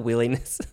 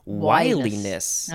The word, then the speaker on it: wiliness: Oh, no, it's not willingness. – Oh, I was going to be like, yes, willingness, this is great. – I was like, what is that word? That would have been spelled with two L's. This is one L, which is wiliness.